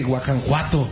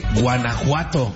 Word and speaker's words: Guanajuato: No se dice Guanajuato, se dice Guanajuato.